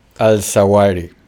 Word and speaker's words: Al-Zawahiri: ¿Cómo se dice? Al-Zawahiri. Al-Zawahiri. – Al-Zawahiri. Exacto.